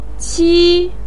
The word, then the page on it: qi1: pinyin